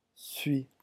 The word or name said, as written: suis